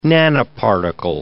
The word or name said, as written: nanoparticle